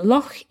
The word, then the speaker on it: loch: So loch is a kind of unusual word in English because this ch sound, this ch that you make in your throat, this doesn't exist elsewhere in English.